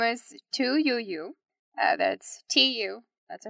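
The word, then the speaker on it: Tu Youyou: And then her last name is Y-O-U. Y-O-U. And I did look up how to pronounce it, and that was what kept showing. So I'm gonna roll with it.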